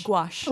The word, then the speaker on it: gouache: Wash? – Gouache. – Oh, gouache. Goodness. – Actually, the word is G-O-U-C-H-E, and I used to call it gouache. I didn't even know how to say it.